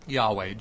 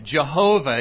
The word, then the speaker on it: Yahweh: Yahweh. Jehovah is another way that this name has been pronounced in our culture. That's actually taken from the German Jehovah, Yahweh.